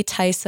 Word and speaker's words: Theis: You can follow me on Instagram at Julie Theis Official. My last name is spelled Theis. And you can also follow me on TikTok, which is just Julie Theis on TikTok.